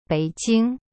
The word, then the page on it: 北京: bei jing